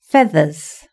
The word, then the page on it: feathers: British English